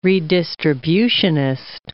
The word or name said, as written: redistributionist